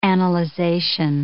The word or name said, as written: analyzation